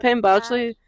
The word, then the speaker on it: Penn Badgley: It's Penn Badgley? How do you say his name? – Yeah, Penn Badgley.